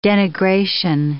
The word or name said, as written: denigration